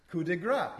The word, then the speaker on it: coup de grâce: Coup de grâce, yes, okay.